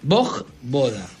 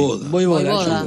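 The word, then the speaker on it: Vojvoda: Boj Boda. Voy por la de luego. – Es como Djokovic. Es Boj Boda.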